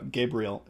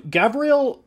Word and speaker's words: Gabriel: In this case, uh, Gabriel is written without a dog. Is it with a ash or without a ash? There's a. It's pronounced with a V in Hebrew.